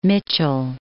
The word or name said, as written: Mitchell